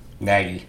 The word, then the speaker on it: Nagy: Nagy.